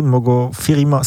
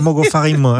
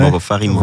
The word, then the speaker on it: Mogo Farima: Bon, surtout quand tu as prononcé Mogo Farima, c'est Mogo Farima. – Hein. Mogo Farima.